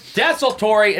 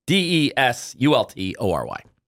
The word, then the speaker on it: desultory: Desultory. It just sounds like the word result, but with a D. – D N O R Y. So D E S U L T O R Y.